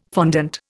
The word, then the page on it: fondant: Indian